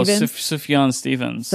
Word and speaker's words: Sufjan Stevens: Sufjan Stevens? – Oh, Sufjan Stevens.